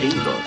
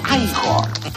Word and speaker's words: Igor: Frankenstein, usted debe de ser Igor. – No, se pronuncia Igor.